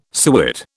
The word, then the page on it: suet: British English